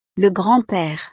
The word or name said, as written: le grand pere